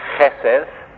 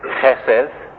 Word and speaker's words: chesed: It is chesed, chesed. You could spell it like an H-E-S-E-D, and you should have to make the H a guttural sound and make the D soft, like D-H rather than a hard D.